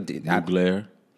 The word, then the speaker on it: Mugler: And I think I said that right. It's a Mugler. – Mugler. Yeah. – I don't know. – No, it is Thierry Mugler. That is how that is the official way to say it.